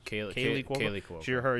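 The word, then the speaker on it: Kaley Cuoco: Kelly Cuoco? Yeah. Is that how you pronounce it? – Yeah. – She's Kaylee Cuoco.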